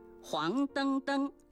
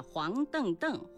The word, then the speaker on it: 黄澄澄: 不是的，黄澄澄属于叠词结构，后 2个叠字登登遵循汉语的语音习惯发生变调，读作音平登，因此整体注音是黄澄澄，而非原调的黄澄澄或是黄澄澄。